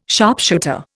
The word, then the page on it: sharpshooter: Indian